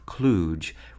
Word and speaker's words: kludge: What we do know is that speakers of American English have agreed to disregard it in pronunciation, making the vowel pronunciation of kludge reflective of the pronunciation of the German word klug, K-L-U-G.